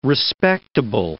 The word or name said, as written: respectable